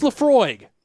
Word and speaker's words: Laphroaig: Oh God, how do you say that one? Larprog, Laphroaig. Laphroaig. – Yeah. – Oh, that's Laphroaig.